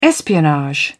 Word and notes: espionage: British English

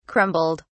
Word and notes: crumbled: British English

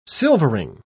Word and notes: silvering: British English

